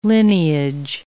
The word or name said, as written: lineage